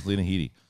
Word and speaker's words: Headey: No, Lena Headey.